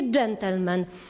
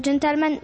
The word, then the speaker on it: gentleman: Kochani, ja mówię gentleman. To jest słowo pochodzące z języka francuskiego. Gdzie pada akcent? Zwróćcie uwagę, w słowniku poprawnej polszczyzny jest zapisana wytłuszczona sylaba. Wytłuszczona sylaba oznacza, gdzie akcentujemy to słowo.